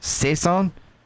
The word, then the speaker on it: Saison: So this, I'm going to say this wrong, and I'm probably going to get a lot of comments on Saison. Saison. Saison Ale. – That sounds about right.